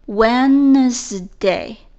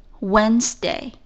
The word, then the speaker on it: Wednesday: 星期三，Wednesday，W E D N E S D A Y，这里有个 D，但是它不发音的。 Wednesday, Wednesday，星期一 Monday，星期二 Tuesday，星期三 Wednesday，这三个词都是以 day 结尾的。